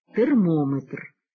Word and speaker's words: термометр: Стосовно ж назв вимірювальних приладів із складовим «метр», то у них наголос ставиться на передостанньому складі «барометр», «спідометр», «термометр».